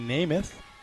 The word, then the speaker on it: Nemeth: They got the pronunciation guide, and it says, because it's spelled N-E-M-E-T-H, so you would think it's Nemeth, but it says parentheses, just like Joe.